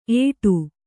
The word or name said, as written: ಏಟು